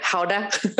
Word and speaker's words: Gouda: Gouda? – Yeah.